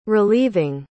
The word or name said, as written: relieving